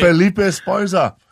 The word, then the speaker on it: Felipe Esparza: Felipe Esparza.